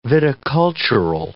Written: viticultural

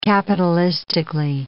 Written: capitalistically

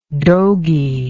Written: dogy

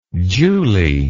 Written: julie

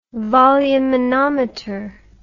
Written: volumenometer

